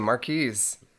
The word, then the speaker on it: Marquise: Am I pronouncing that right? Marquise? – Yes, that is the name, Marquise.